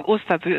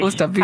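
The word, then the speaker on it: Osterby: Das haben Sie jetzt schon erzählt, und am Telefon bin ich jetzt verbunden mit Malermeisterin in Osterbay, wie wird es ausgesprochen? In Schleswig-Holstein auf jeden Fall. Guten Morgen. – Guten Morgen, Osterbü ist richtig. – Osterbü, hallo.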